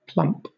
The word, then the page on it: plump: British English